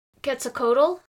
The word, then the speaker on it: Quetzalcoatl: And this was governed by a god you've probably heard the name of, Quetzalcoatl.